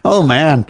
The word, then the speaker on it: Oman: O-M-A-N. I guess it's Oman. Oman.